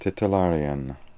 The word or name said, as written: titillarian